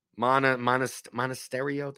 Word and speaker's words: Monasterio: Berger, Soler, Montesario. Monasterio, that's how you pronounce it.